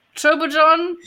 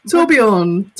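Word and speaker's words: Torbjörn: Tobal John? – Torbjorn.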